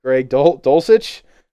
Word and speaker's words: Greg Dulcich: Greg Dolt, I'm going to butcher his name all season. Greg Dulcich, he looks like baby Kelsey.